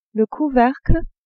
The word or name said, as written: couvercle